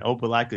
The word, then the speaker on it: Opelika: Opelika, tell me if I didn't say that right, Alabama. And yoga is also a part of this communal experience. Welcome, Representative Jeremy Gray. – Thank you. Thank you for having me. And Opelika is right.